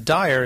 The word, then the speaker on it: Dyer: I joke here that Everyone, there's a, my last name, Dyer, is also a street called Dyer Road here, and people always say the street name correctly, but when they say my name, they always say it wrong.